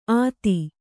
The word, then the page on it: ಅತ್ತಿ: atti